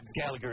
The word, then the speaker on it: Galaga: Yeah, Galaga.